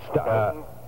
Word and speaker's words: Stoughton: I delivered in Stoughton, is that how you say it? – Stoughton.